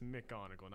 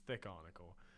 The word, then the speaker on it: McGonagall: It's Miss McGonagall, not Miss Thickonagall.